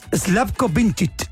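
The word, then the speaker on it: Slavko Vinčić: Slavko Vincic.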